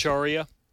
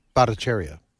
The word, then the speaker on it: Bhattacharya: How you pronounce Bhattacharya? – Huh? – Dr. J. Bhattacharya. – Bhattacharya.